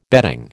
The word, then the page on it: bedding: American English